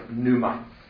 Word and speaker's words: pneuma: Pet uttalas inte, utan liksom pneuma.